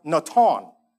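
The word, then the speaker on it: natan (נָתַן): First meaning signifies deliver over, which is the word that you find in Hebrew. It's pronounced natan, okay?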